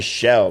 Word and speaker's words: Shell: My last name is Shell, like the gas station. People always put a C in it.